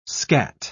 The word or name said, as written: skat